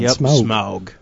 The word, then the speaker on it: Smaug: Good pronunciation on Smaug.